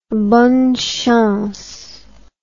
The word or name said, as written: bonne chance